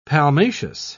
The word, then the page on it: palmaceous: British English